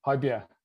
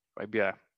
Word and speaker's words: Højbjerg: Højbjerg. – Højbjerg.